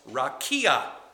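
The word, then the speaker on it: rakia (רקיע): It's pronounced rakia. Rakia. Should we all say that? Rakia. Three syllables, rakia.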